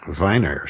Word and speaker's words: Wien: And I explained to the waitress that this is named after the city of Vienna, where the name is spelled W-I-E-N, Wien. And in European languages, I-E is almost always an E sound, and E-I is an I sound.